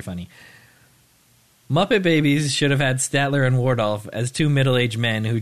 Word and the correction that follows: Waldorf: not to correct your pronunciation, but Statler and Waldorf.